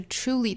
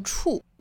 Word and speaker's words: truly: 首先我们做英音的语音讲解，我们需要注意的第一个单词就是 truly 的发音。其实这个词我们之前早读出现过很多次，包括像 true, true, 还有 truth, truth。难点呢其实就在于 t, r, u, 这三个因素组合在一起的发音。很多同学会发成触，啊，就是像我们汉语里面触碰的这个触的音了。其实应该是一个 t, r, tr, tr, 再去过渡到 u,